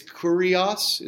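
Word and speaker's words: Kurios (Κύριος): In Greek, it's Kurios is kind of how it's pronounced. We kind of made the U a Y, and so we say Kyrie eleison in Latin.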